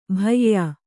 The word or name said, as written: ಭಯ್ಯಾ